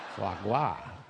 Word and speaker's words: foie gras: Foie gras.